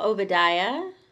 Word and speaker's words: Obadiah: Well, Obadiah. – You look unsure. It is right.